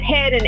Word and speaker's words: Headen: And that's Hedden, H-E-A-D-E-N, guys, if you're wondering how to spell that. I know it's different ways to spell Hedden. It's my maiden name, so H-E-A-D-E-N.